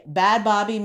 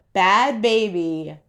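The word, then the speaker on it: Bhad Bhabie: So I am just like, okay, Bad Bobby made Bad Bobby. That's how I used to pronounce her shit until I looked it up. Bad Baby made 57 million.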